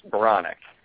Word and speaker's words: Baronak: It's Baronak.